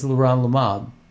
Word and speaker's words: leronlimab: The drug is called leronlimab, which is spelled L-E-R-O-N-L-I-M-A-B, leronlimab.